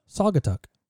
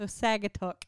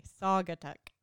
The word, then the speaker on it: Saugatuck: Saugatuck. – Saugatuck? – Saugatuck Brewing Company. – Saugatuck, okay. – Yeah. – So Saugatuck ain't right. – Absolutely not. – Okay, Saugatuck.